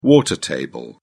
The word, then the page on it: water table: British English